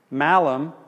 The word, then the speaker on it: malum: Because in Latin, malum, short A, is a word for fruit, apple. Malum, same spelling, long A, is evil and bad. So the fruit that introduced evil and badness is malum, because it's malum. Same spelling, short A, long A.